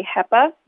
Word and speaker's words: HEPA: You can purchase a HEPA, which is spelled H-E-P-A, a HEPA air purifier.